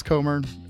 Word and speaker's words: Comer: Is that how you pronounce Comer? I should have checked. – Yeah, you got it.